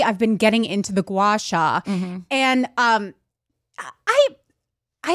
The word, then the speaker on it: gua sha: Well, gua- I've how do you pronounce it? Gua- it's a gua sha.